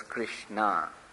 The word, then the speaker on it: Krishna: That's why her name also was Krishna, not Krishna. Actually, name of Krishna is ends with a short A and Draupadi's name ends with a long A.